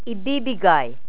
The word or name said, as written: ibibigay